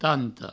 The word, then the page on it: tanta: provençal; tanto